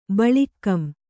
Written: ಬಳಿಕ್ಕಂ

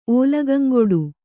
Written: ಓಲಗಂಗೊಡು

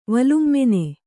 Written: ವಲುಮ್ಮೆನೆ

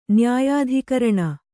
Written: ನ್ಯಾಯಾಧಿಕರಣ

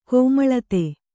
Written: ಕೋಮಳತೆ

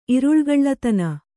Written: ಇರುಳುಗಳ್ಳತನ

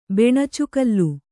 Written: ಬೆಣಚುಗಲ್ಲು

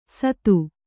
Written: ಸತ್ತು

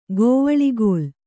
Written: ಕವಳುಗೋಲ್

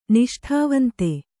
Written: ನಿಷ್ಠಾವಂತೆ

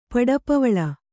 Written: ಪಡಪವಳ

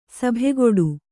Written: ಸಭೆಕೂಡು

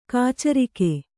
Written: ಕಾಚರಿಕೆ

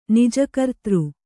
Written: ನಿಜಕರ್ತೃ

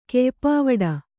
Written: ಕೆಯ್ಪಾವಡ

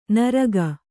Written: ನುರ್ಗು

